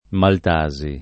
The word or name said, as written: maltasi